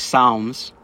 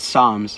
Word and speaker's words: Psalms: Apparently it's not called Psalms, it's called Psalms.